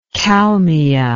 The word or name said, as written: kalmia